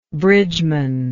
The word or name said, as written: Bridgman